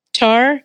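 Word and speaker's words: Tár: Tar? – Yeah.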